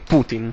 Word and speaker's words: Putin: Putin, è scritto Putin... Giusto? Giusto? – Sì, benissimo. Sì, no, si legge così, Putin. – Ecco. – Per come è scritto? – Sì. Se si legge alla francese, non viene Putin? – Sì, più o meno sì.